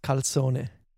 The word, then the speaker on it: calzone: And yes, we do have calzone in Italy. We pronounce it differently. – How do you pronounce it? – It's very different. It's calzone. – Oh, that's not that different. – Okay. It's, I guess the accent is different.